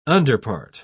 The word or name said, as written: underpart